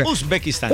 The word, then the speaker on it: Uzbekistán: Uzbekistán. – ¿Qué tal mi pronunciación? – O sea, Uzbekistán. – Uzbekistán.